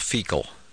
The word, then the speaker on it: Feakle: And I went back to Ireland a couple years ago to where my grandfather's from, and it's a little town called Feakle. I swear to God, it's in County Clare. And it's spelled, spell it? – It's spelled differently, but it smells the same. It's F-E-A-K-L-E, I think.